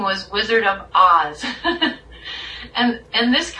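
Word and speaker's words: Oz (עז): And then that's a Hebrew word that's pronounced Oz.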